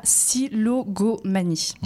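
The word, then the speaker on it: syllogomanie: Et, c'est un degré tel, en fait, qu'on parle ici du trouble pathologique de la silogomanie. Mmh. Je l'ai bien prononcé, c'est bon.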